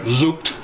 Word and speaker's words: Zucht: See the sermon Zucht. I never say it right. Zucht. Z-U-C-H-T. German word for discipline.